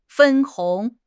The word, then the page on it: 分红: HSK6